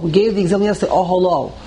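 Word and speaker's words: Oholo (אהלה): The word Oholo is spelled with a He, and we pronounce it with a Vav.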